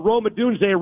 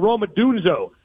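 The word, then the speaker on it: Rome Odunze: You know, Scott Van Pelt called Roma Dunze Roma Dunzo on TV.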